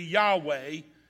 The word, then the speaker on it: Yahweh: So the closest we can come to pronouncing this name would be Yahweh, or its English form, which is Jehovah.